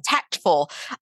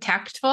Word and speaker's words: tactful: The moment I said tactful, I was like, we don't say that. – We don't say tactful. We don't pronounce that T so much unless we're over articulating for English language learning purposes.